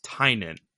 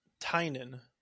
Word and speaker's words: Tynion: And he says, Good tai nin. Bad tin en tai ni in. I'll be honest, I didn't know how to say it till I heard it. So his name is Tynion.